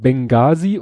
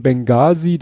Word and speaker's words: Benghazi: Und in diesem Benghazi, deswegen, weil da ein H drin steckt, was man nicht spricht, daher kommt dieser Spruch, the age is silent.